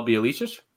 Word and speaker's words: Bjelica: Oh, Bjelica? – Yeah, there we go.